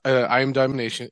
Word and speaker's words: I Am Domination: It's called I Am Domination. – Yeah, it's I Am Domination. And thank you for that's why we fuck with you, dude, because you say it right. Everybody's like, oh, Domination. Like, no. – I'm really right.